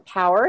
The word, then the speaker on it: Paour: Well, my website is Wholehearted Courage, and that's Whole, H-O-L-E. I also have on LinkedIn, my name is Catherine Power, and it's spelled P-A-O-U-R.